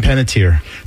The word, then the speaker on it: Panettiere: Hayden Panettiere.